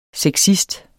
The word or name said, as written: sexist